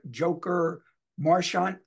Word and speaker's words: Joker Marchant: Joker Marchant Stadium, I believe that's how it's pronounced. Mm-hmm. Stadium, right? That's how it's pronounced. – Stadium, yeah, just like that. You nailed it. Nailed it in one.